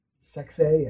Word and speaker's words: Sexay: Am I saying it right? – Sexay, yeah. Jerry the King Lawler's son.